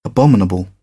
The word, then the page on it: abominable: British English